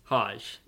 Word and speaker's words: Hajj: I want to share this because I recently watched a documentary on YouTube about Hajj, the... – Doge? – Hajj. H-A-J-J. – D-O-G-E? – No. H-A-G-G. J-J. H-A-J-J. – Hajj. J-J-H-A? – Hajj.